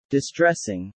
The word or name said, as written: distressing